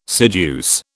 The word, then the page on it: seduce: British English